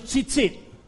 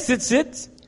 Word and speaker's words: tzitzit: tenían los tzitzit. – They had the, ¿cómo se dice? – Tzitzit.